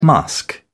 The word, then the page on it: musk: British English